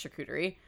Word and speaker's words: charcuterie: I think it's charcuterie. – Yeah, it's charcuterie.